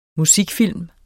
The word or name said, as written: MUSIKFILM